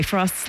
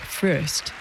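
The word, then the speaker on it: Fröst: By the way, Frost's last name is spelled with two dots over the O in umlaut. So in his native Swedish, it's pronounced more like Frost. But in English, everyone seems to just agree to pronounce it Frost.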